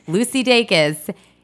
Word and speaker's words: Dacus: I think it's pronounced Dacus. It's D-A-U-C-U-S. – Gotcha. – No U in the first part. D-A-C-U-S. Fuck, it's Lucy Dacus. No wonder it's not pronounced Dacus. Lucy Dacus. D-A-C-U-S.